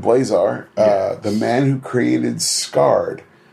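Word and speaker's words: SARD: I understood it's S-A-R-D, but we pronounce it Scarred.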